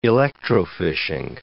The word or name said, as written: electrofishing